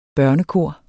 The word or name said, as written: børnekor